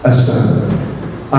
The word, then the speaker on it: Assange: Assange, is that how you pronounce it? Assange.